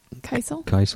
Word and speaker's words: Kiesel: Kiesel.